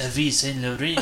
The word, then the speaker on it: Yves Saint Laurent: And she's like, actually, sugar, it's pronounced Yves Saint Laurent.